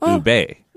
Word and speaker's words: ube: Or some people might even pronounce it ube.